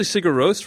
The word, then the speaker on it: Sigur Rós: Is that how you pronounce it? – It's usually Sigur Rós, from what I understand. – Sigur Rós. That sounds way more Icelandic.